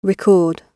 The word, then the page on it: record: British English